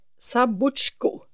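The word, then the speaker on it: Zabuzhko: Hon heter Oksana Sabotska. Nej, Sabotsko, om jag uttalar det rätt.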